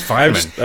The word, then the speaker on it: Feynman: Is it Feynman? – Yeah. – Apparently. – Yes. – I don't know how to pronounce it. Yeah, it's Feynman.